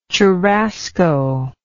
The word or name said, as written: churrasco